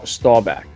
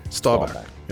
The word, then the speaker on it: Staubach: How do I say it proper? What's the proper pronoun? – Staubach. – Staubach. Yeah, okay, good.